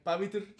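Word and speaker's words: Pavitr: ¿Cómo se dice Pavitur? Pavitur. Pavitur.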